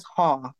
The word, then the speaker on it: Hall: And then my last name is Hall, like a hallway, H A L L.